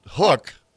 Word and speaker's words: Huk: how to pronounce H-U-K? – Well, that I you've always said Huck. – It's Huck. – Huck.